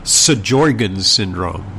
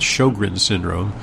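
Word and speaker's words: Sjögren's: S-J in Swedish is pronounced sh. So it's, if you wanted to read it phonetically from the way it's spelled, it would be Sjogren's syndrome, but it's Sjogren's syndrome.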